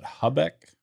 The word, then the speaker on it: Habeck: The German economic minister, how do we pronounce that? Habeck? – Good enough. – Habeck came out and said that citizens should prepare for 5 tough years ahead.